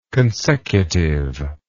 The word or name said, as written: consecutiveness